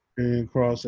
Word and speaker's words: Karrion Kross: Was it Karrion Kross? Am I saying it right? – Karrion Kross, aka Killer Kross.